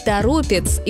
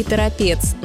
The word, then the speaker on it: Торопец: Один из таких примеров – Торопец из Тверской области, хотя в новостях можно услышать и Торопец, но это ошибка.